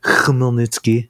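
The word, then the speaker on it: Chmelnytsky (Хмельницький): In fact, I have a cool story about that I'll tell in a few minutes that happened in a town called Chmelnytsky. You have to say it like that, like with a little bit of in the back of your throat.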